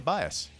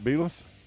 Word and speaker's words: Bias: Yeah, it's Jay Bias. – It's not Bielus? – No, Bear, it's not Bielus.